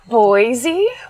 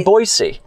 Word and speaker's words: Boise: I do want to get back to the word B-O-I-S-E. I'd like everybody to say that right now, because I've recently been told I say it incorrectly. – Boise? – I say Boise. – They want you to go with a hard S. Boise.